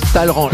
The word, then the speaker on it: Talleyrand: Alors, ça s'écrit Talleyrand, mais ça se prononce Talleyrand.